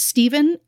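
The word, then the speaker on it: Stephan: And while it is pronounced Stephen, it is spelt with an A-N at the end in Stephanie's honor. So it basically looks like Stephanie, right?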